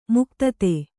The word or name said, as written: ಮುಗ್ಧತೆ